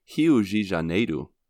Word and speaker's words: Rio de Janeiro: In Portuguese, you say it Rio de Janeiro, which is quite different than what we say uh, because their R's at the beginning of the bit. words are pronounced like an H.